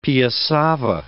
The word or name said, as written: piassava